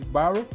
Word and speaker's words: Barrow: Pronuncia-se Barrow e B A R R O W,